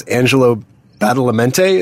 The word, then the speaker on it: Angelo Badalamenti: is Angelo Badalamenti. Is that how you say his name? – I believe so.